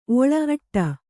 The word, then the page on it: ಓಳಿ: Kannada